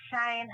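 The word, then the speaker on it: Chayanne: Hello, my name is Cheyenne Hubbard. Thank you for listening to radio, Guess Who's Coming to Kansas City. And you can contact me on Facebook. My name is spelled slightly different. It's C-H-A-Y-A-N-N-E Hubbard.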